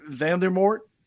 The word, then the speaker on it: Voldemort: For example, Lord Voldemort.